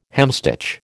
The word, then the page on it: hemstitch: American English